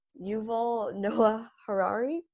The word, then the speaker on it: Yuval Noah Harari: It's by, I hope I'm saying his name right as well. It's Yuval Noah Harari. – That's exactly um, right.